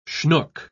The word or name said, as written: schnook